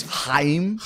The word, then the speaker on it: Chaim: Well, let me pronounce it with you. It's Chaim. – Chaim. – That's the... – Except you're doing more. It's more... Like, it's like a cat throwing up a hairball. – Chaim.